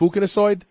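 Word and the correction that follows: fucoidans: No, it's way off. It's fucoidins.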